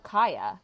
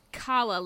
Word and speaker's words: Calla: but did you guys pronounce it Calla or Kaya? – It's Calla, like Calla lilies. – C-A-L-L-A?